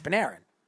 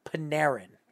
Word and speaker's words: Panarin: Pronounce his name right. It's Panarin. – Say it again. – Panarin. – Panarin. – Yes, Panarin.